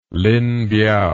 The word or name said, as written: linpiao